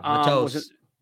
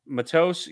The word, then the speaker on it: Matos: Yeah, you mentioned somebody else on the side of Brian Burns, and I'm going to butcher this name. Yatira Gross Matos. – Yeah, Matos. – Matos, yeah.